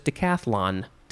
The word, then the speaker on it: decathlon: And again, I thought there was another syllable in there. No, it's just decathlon.